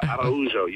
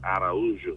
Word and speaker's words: Araújo: No, that was very good. Arujo. You missed the extra A there. A-R-A-U-J-O. – Okay, so it's Arujo. – Arujo, yes.